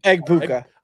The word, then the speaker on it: Egbuka: Would you think how Emeka Ekugba, am I saying that right? – Egbuka.